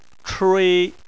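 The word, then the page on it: chui: tone 1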